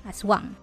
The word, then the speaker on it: Asuang: I completely forgot that we have a god named Aswang in Bicol, and it is spelled as A-S-U-A-N-G, Aswang.